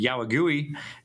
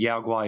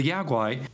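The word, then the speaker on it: Yao guai: But the Yaogwai, which is how I pronounce it, I think it's pronounced, because like when I was a kid, I always called it the Yaogui because it is spelled with,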